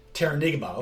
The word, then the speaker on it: Terranigma: Maybe you can help me play pronouncing of this. – Terranigma? – Terranigma, okay, alright.